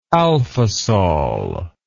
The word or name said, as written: alfisol